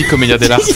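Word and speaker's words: commedia dell'arte: Non, parce que ça se dit comédia dell'arte.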